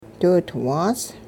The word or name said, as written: diwidwaas